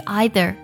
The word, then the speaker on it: either: 第一个是 either，它的 e i 呢读作 i 的音，然后注意一下 t h 音呢，我们读的时候呢，它是咬舌音 z，either 这么去读。